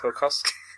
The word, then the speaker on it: Tarkowski: Bailly, the Burnley defender, Tarkowski. Is that how it's pronounced? – Tarkowski.